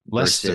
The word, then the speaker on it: Leicester: Leicester City, I believe is how you say it. – Leicester.